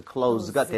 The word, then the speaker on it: close: S는 Z 비슷하게 발음을 해서 close가 되는데, 자, 이거 나온 김에 여러분들 같이 항상 여러분 교재에 필기해 주세요.